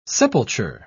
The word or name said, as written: sepulture